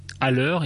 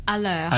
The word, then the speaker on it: à l'heure: À l'heure is on time. It's spelled A with a kind of accent grave on A, space, and then L apostrophe H E U R E. – À l'heure.